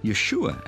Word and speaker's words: Yeshua: Well, they didn't actually call him Jesus, but Joshua, or Yeshua, as it's pronounced in the Hebrew.